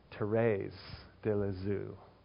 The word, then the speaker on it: Thérèse de Lisieux: She was a Carmelite nun named, let me see if I can say it right, Thérèse de Lisieux, but it looks like Teresa.